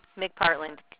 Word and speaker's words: McPartland: And Valerie, it's spelled just V-A-L-E-R-E-I-E, sorry. – Correct. – Okay, and what is your last name, please? – McPartland, capital M-C, capital P as in Peter, A-R-T-L-A-N-D.